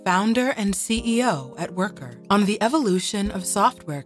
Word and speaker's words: Wercker: I am the CEO and founder of Worker, W-E-R-C-K-E-R. So it's spelled in a sort of European Germanic way, but it's pronounced as Worker.